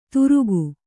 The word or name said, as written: ತುರುಗು